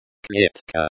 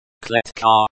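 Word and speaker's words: клетка: Klitka. Klitka, stress on the first syllable.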